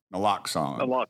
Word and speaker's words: naloxone: Naloxone.